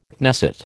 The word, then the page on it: Knesset: American English